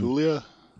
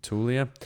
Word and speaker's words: Taulia: They did get a transfer from Tua Tagovailoa's uh, younger brother, Taulia Taulia. I'm not exactly sure how to pronounce his first name. Taulia. – Taulia.